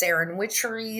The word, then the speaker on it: SerenWitchery: Did I pronounce that right? – You did. It's a play on the word serendipity. So, yes, SerenWitchery, serendipity, because magic is very serendipitous.